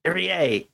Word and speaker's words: Irie: Irie. EA. Yeah. EA Sports. I forgot. Damn it. – Yep. Irie Sports.